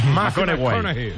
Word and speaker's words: McConaughey: Matthew McConaughey. – Sí, sí, sí, sí. – Hay que ir corrigiendo la pronunciación. – McConaughey.